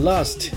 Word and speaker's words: last: 这个里边 last 这个 t 是轻轻的一带而过，可以不发音哈。